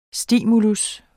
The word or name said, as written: STIMULIENE